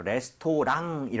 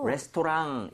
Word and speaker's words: restaurant: 레스토랑 이렇게 하지 마시고요. RANT 부분을 살짝 run이죠, run. – 예. – 그냥 비슷하게 해주시면 조금 더 맛이 살아요. 그래서 그래요? – restaurant 이렇게 하지 마시고요.